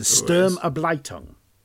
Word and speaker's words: Sturmabteilung: Because we're going to talk about the SA, and you can correct my pronunciation if I get this wrong, if you'd be so good, the Sturmabteilung. – Yes, that's it.